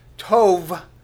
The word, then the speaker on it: Tov: Tov, probably be transliterated into a spelling of T-O-W-B, but it's actually pronounced T-O-V-E, because usually in the Hebrew, the B sounds like a V.